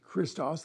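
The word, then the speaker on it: Christos (Χριστός): And so Christ, in the Greek, is Christos, which we say is Christos. The X is a CH sound, hard C.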